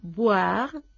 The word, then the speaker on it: boire: El verbo boire, verbo en este caso del tercer grupo, con infinitivo en RE, tenemos que cuidarlo en la pronunciación y distinguir la B de la V, la bilabial de la labio dental. Boire, beber, voir, ver.